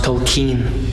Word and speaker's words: Tolkien: It's pronounced Tolkien.